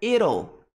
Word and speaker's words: it'll: Então, it will, contraído é it'll.